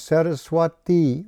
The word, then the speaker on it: Saraswati: Saraswati, that's with a long I at the end, Saraswati Goswami. And thus we are known as Saraswatas. See that with the first A, Saraswata.